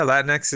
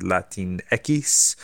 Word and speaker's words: Latinx: Some people have tried to just speak it phonetically, Latinx, or now I'm seeing people suggesting Latinx, which is a Spanish pronunciation of the X.